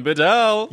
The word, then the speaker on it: Bedell: Colin Bedell.